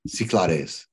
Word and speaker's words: Cyclades: Cyclades.